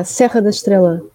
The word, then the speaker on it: Serra da Estrela: Estrelas? Is that right? Estrelas, the mountains? – Ah, Serra da Estrela.